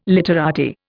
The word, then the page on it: literati: Indian